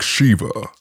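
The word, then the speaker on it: Sheva: Oh, like the guy says like Shiva in the game.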